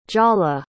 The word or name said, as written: jaala